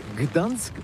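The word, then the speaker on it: Gdańsk: Ça s'écrit G-D-A-N-S-K. On dit Gdansk ou Dansk, on prononce le G ou pas?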